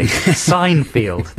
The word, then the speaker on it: Seinfeld: Yes, I'm saying it phonetically. Seinfeld. There's a sign next to a field. Seinfeld.